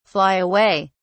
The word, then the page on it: fly away: British English